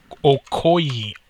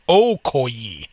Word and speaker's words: Okoye: Okoye. Okoye.